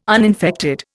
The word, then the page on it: uninfected: Indian